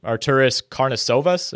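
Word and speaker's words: Arturas Karnisovas: But I read Nuggets GM Arturis Karnisovas. Am I pronouncing that correctly? – Yes, correct.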